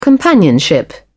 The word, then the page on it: companionship: British English